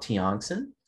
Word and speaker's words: Tiongson: And for me, you can find me by at Rob Tiongson.